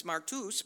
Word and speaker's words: martus: It's pronounced a little bit different. It's martus, but it's the same word.